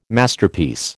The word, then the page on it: Masterpiece: American English